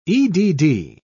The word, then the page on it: edd: British English